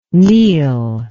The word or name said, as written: neil